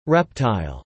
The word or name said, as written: reptile